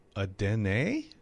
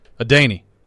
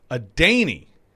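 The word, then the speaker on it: Adeniyi: I'm going to go Adene? Adene? Is that what you're telling me? – Adeney. – Adeney. – I'm just telling you what the pronunciation guide says.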